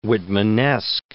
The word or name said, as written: Whitmanesque